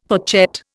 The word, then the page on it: fourchette: Indian